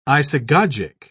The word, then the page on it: isagogic: British English